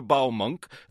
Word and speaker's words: Baumunk: A typical missive from Christopher Baumunk, that's bow as in cow and the rest rhymes with skunk, says here in our heads there's a lot going on.